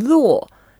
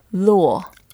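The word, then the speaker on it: luo (络): And then luo, the second word, is the fourth tone, luo. – Luo. – Perfect pronunciation.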